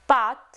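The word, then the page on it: pâte: language French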